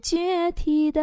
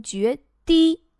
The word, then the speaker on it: 决堤: 不再是决堤的海，正确读音应该读决堤。